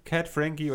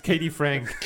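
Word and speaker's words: Kat Frankie: Denk an dich, featuring Cat Frankie oder wie du sie ja. nennst, Katie Frank.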